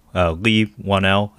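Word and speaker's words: Leigh Whannell: Lee Whannell, I believe is how you pronounce his name.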